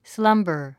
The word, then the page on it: slumber: slʌ́mbə r; スランバァ